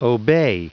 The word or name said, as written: obey